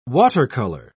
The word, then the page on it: water-color: British English